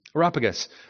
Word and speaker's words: Areopagus: Areopagus.